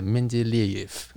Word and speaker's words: Mendeleev: 啊，然后 Mendeleev，然后他真的真的，你干嘛？ – 我感觉就是，嗯，啊，翻译的真好。 – Mendeleev，门德，呃，应该是说他一般翻译叫门德列夫啦。 – OK，那但是因为他的念就是要念成 Mendeleev，所以他就是门德列耶夫。